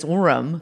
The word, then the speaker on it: Orem: The incident in Utah is from 2008, and that's when his ex-girlfriend says that Rossi came over to her home in Orem. – Mm-hmm. – Fucking Utah-ass name. That actually sounds like, Orem sounds like the name of like a Utahn. – Orrin. Orrin Hatch. – Yeah, but it's Orem.